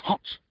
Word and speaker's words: Hans: And he said, no, it's not Hans, it's Hans. Hans, he's a German guy. Hans, H-A-N-S. But in Germany, that's pronounced Hans.